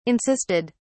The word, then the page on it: insisted: British English